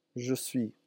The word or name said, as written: je suis